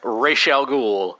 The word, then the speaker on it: Ra's al Ghul: And Batman the Animated Series, it's Ra's al Ghul, full stop. – It is Ra's al Ghul, David Warner, dropping that on you.